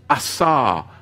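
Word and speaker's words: asar (אסר): It's an interesting bound, interesting Hebrew word, asar.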